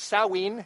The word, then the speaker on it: Samhain: We are here celebrating, how do you ever say it, Samhain. I think that's the way the Wiccans pronounce it, with a wonderful Wiccan community.